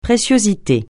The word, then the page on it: préciosité: francais